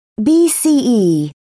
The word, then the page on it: bce: British English